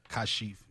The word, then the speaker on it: Kashif: Kashif, yes.